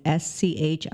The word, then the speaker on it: Schildhauer: Schildhauer? – I don't think that's that hard. – No, I could have pronounced it wrong. It's Schildhauer.